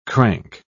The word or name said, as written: crank